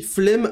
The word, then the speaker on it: flemme: Ça s'écrit flemme avec deux M et ça se prononce flemme.